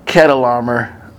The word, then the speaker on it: Chedorlaomer: And in Genesis 14:5, it says that in the 14th year, and this is Chedorlaomer, sometimes pronounced with a K, Chedorlaomer,